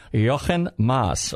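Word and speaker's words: Jochen Mass: He's a German. Jochen Maas. Okay. – Yeah. – That's how you pronounce it.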